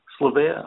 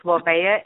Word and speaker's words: Slaveia: Slaveia, I would like you to stay on the line and Raj stay on the line.